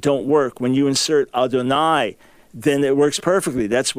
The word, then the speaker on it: Adonai: Okay, the further you get into it, you will see that the Masoretic scribes very clearly were understanding that this word you're going to pronounce Adonai, and that's indicated by the vowels before it and afterwards.